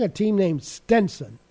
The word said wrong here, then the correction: Stetson: Stetson. – Yeah, that's how you pronounce it. S-T-E-T. – Yeah, just like the cowboy hat, Stetson.